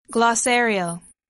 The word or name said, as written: glossarial